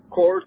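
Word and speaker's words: Korçë: It's going to be Korch. I'm going to spell it. K-O-R-C-E.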